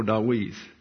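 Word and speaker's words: Dawith (דוד): You see in the parenthesis there, but it's pronounced as an English W, therefore Dawith.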